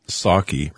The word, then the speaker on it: Psaki: but one thing that is troublesome is Jen Psaki. Remember, the P is silent, just like the P in president for Biden.